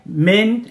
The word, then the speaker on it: Minh: Min, it's spelled M-I-N-H. It's Vietnamese. So you would just think, like, you know, okay, Min, not Min-H. Min.